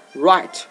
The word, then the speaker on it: right: 这个单词你还不认识的话，你可以变成一个你非常熟悉的，就是 Right， R-I-G-H-T。你有没有发现在这里 Right 这个单词 G-H 是不发音的，是吧？